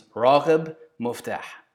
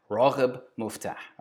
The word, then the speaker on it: Raghib Muftah: Okay, his name was kind of funny, and I don't know Arabic, but I know how this is how you pronounce it. His name is Raghib Muftah. Raghib Muftah.